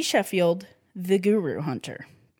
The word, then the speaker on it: Scofield: Sheffield. Last name or first name B.E. Last name S-C-O-F-I-E-L-D, but appears to be pronounced Sheffield.